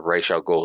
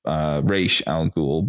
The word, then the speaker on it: Ra's al Ghul: As well as it's not the concept of Ra's al Ghul,